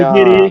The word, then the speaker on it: Yugiri: Bem, eu queria também falar sobre a Yu Girl. A Yu Girl, ela é Yugiri. a personagem. – yu yu Giri, droga. Sempre esqueço como pronuncia.